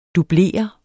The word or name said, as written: dublere